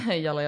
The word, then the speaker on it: Yalla: Yalla, yalla. – Wait, am I pronouncing that correctly? – Yalla. – Yalla. – Yalla. Yeah. Yeah, you got it.